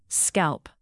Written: scalp

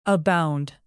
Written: abound

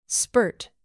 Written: spurt

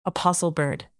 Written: apostlebird

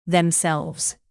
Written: themselves

